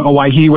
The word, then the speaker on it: Owyhee: And so in honor of those expedition members, the area got its name, Owyhee, which is actually the native Hawaiian pronunciation of Hawaii.